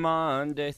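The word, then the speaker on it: Maundy: And Monday, Thursday.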